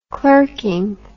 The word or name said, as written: clerking